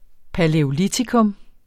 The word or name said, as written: palæolitikum